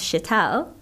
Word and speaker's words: Sheetal: It became anglicized and it's actually pronounced Sheetal and means cool and calm.